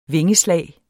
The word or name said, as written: vingeslag